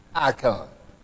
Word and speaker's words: eikon: The Greek word translated image is spelled E I K O N. It's pronounced icon.